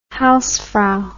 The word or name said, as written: housefrau